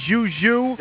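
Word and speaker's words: Wang Zhizhi: was at the game last night when Wang Zhuzhu, do I pronounce that correctly, Zhuzhu? – You're right on.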